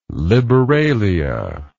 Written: liberalia

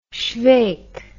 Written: schweik

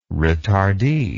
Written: retardee

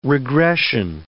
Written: regression

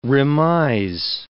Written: remise

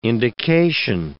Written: indication

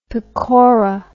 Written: pakora

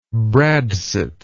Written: bradsot

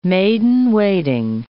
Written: maid-in-waiting

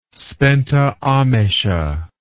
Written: spenta amesha

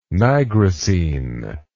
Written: nigrosine

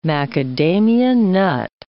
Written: macadamia nut

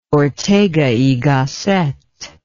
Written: ortega y gasset

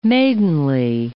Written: maidenly

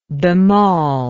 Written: bemaul

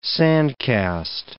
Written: sand-cast

